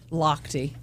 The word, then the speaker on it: Lochte: Lochte. – Her name is Ike. – Huh? – His mom's name is Ike. – That can't be. – Yeah, Ike Lochte. Loche? – Lochte.